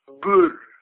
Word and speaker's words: Boer: Our ethnic name is actually pronounced Boer. And it's actually the Dutch word for farmer.